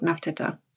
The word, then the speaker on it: Nefertiti: But I'm Wikipedia, a scholarly journal, Wikipedia tells me it's pronounced something like Nafteta.